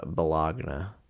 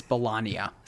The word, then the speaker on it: Bologna: It's actually pronounced Bologna. – It's not. Don't listen to him. It's Bologna.